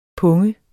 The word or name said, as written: pung